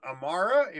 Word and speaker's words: AMAARA: She's got her musical projects called AMAARA, if that's correct.